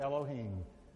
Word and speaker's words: Elohim: And the pronunciation is right there, Elohim.